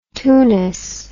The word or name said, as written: twoness